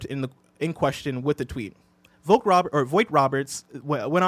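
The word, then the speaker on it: Vogt-Roberts: Jordan Voight Roberts, did I pronounce that correctly? – Voight Roberts.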